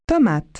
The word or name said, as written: tomato